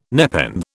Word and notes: nepenthe: British English

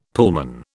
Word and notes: Pullman: British English

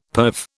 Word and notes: perve: British English

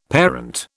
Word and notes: parent: British English

